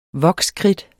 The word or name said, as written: vokskridt